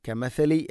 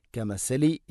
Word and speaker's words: كمثل: ഇവിടെ കമഥലി എന്ന പദത്തിലെ ഥ, അതിൻ്റെ ഉച്ചാരണം ശ്രദ്ധിക്കണം. കമസലി എന്ന് ഓതരുത്, കമസലി ഷൈത്താനിയല്ല, കമഥലി ഷൈത്താൻ.